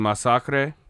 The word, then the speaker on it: Masacre: It's spelled just like the English word massacre. It's called Masacre.